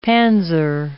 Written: panzer

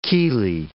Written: chelae

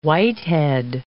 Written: whitehead

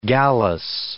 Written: gallus

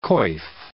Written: coif

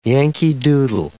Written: Yankee-Doodle

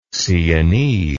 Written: sienese

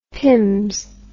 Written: pimms

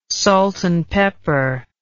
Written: salt and pepper

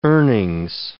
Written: earnings

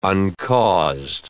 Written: uncaused

